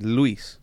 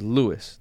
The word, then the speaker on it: Luis: No, so my name is Luis, right? I pronounce it as Lewis to make it more American sounding, right?